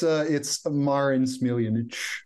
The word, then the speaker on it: Marin Smiljanic: It's Marin Smiljanic.